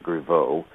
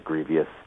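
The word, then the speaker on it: Grevioux: You guys pronounce it Griveaux, which would make sense, because that's what the French word would be. However, he pronounces it Grievous.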